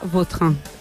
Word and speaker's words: Vautrin: Es cada que a mí me suena mejor Vautrin. Es como... – Da más fuerza, ¿no? – Más puede ser más la pronunciación. – Vautrin. Sí. Elegante. – La original francesa, Vautrin.